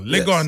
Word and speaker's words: Legon: And then on the 5th of December at Lagos Sports Stadium, Did I say that right? – Legon yes. Sports Stadium. I said Lagos, a Nigerian.